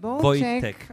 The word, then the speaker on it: Wojciech: Wojciech, is dat goed uitgesproken? – Wojciech, oké. Okay.